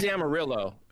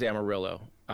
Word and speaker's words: Amarillo: It is Amarillo. The actual name is Amarillo, but I always call it Amarillo.